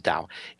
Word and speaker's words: Dao: What I discovered, and that's the Tao, or the Chinese pronunciation is Dao.